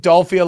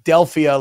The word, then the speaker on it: Philadelphia: No, you're saying You're saying Dolphia like Dolph Lundgren, and yeah. – Most people pronounce it Delphia like the Oracle of Delphi.